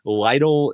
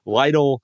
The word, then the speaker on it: Lidl: I'm worrying about Aldi and Lidl. And I think I'm pronouncing Lidl correctly, L-I-D-L, and Target, and, you know, and some others.